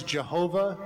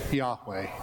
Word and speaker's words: Yahweh: That sacred group of Hebrew letters that our hymn pronounces Jehovah, but we usually say Yahweh.